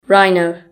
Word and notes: rhino: British English

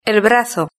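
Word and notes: el brazo: Spanish (Spain)

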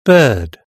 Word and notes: bird: British English

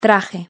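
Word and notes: traje: Spanish (Spain)

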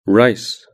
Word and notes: rice: British English

